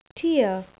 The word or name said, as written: tier